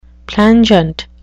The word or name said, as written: plangent